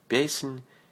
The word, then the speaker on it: Песнь: Песнь песней. Песнь песней. Песнь translates as canto to English. Say нь. Нь. Песнь. Soft sign on the end of letter N. Нь.